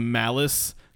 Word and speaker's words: Malice: His name is Malice. Kind of rhymes with phallus.